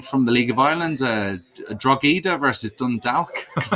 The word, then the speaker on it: Drogheda: But in Louth, there are two towns, Drogheda and Dundalk, and we hate each other. – And Drogheda is the one with silent G in the middle.